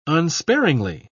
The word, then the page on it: unsparingly: British English